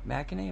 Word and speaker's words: McEnany: McEnany, am I saying that right? – Right.